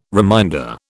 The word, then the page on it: reminder: British English